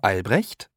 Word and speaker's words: Albrecht: P-E-T-E-R und dann S-C-H-L-O-S-S-E-R. – Zwei. – Sie sind Frau Albrecht? – Ja, mein Name ist Jutta Albrecht. Jutta schreibt man J-U-T-T-A und Albrecht, meinen Nachnamen, A-L-B-R-E-C-H-T.